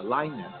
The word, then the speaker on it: Lyness: That was Linus. And it's spelled L-Y-N-E-S-S. Not like Linus with the thumb sucking in the blanket. from the peanuts.